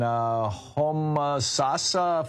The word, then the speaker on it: Homosassa: Picking up your calls here, Charlie in Homosassa, Florida.